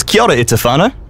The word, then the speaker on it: kia ora e te whānau: Is kia ora e te whānau? – Mean, I'll make sure to remember that.